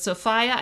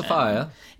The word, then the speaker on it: Sophia: So apparently, and Nicole does mention this, Nicole says, Sophia, I believe, is pronounced with a long I. Apparently, that's the old British pronunciation. – Sophia.